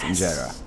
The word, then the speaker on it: injera: But the thing I always come back to that I'm fascinated by is the bread, the injera. Did I pronounce it right? – Yes, you did pronounce it right. It's injera.